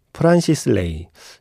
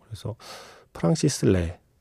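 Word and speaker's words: Francis Lai: 프란시스 레이. 이게 우리가 지금까지 정말 계속 프란시스 레이라고 얘기를 해서 너무 익숙한데, 프랑스에서는 프랑시스 레라고 발음을 하더라고요. 그래서 프랑시스 레.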